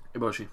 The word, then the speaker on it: Eboshi: Once there, he becomes inextricably entangled in a bitter battle with Matches, Lady Eboshi? – Mm-hmm. Eboshi.